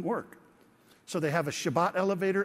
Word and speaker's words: Shabbat: It's Sabbath. That's our English pronunciation. But the word in Hebrew is Shabbat.